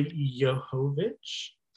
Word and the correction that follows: Jovovich: Johovich, huh? I, I Jovovich.